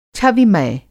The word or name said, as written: छविमय